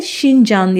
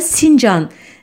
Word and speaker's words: Xinjiang: E, aynı şekilde Çinlilerin bölgeye verdikleri yeni topraklar anlamına gelen Xinjiang adı da gayet ideolojik. E, bu e, terim Türkçe'de Şincan ya da Sincan diye telaffuz ediliyor.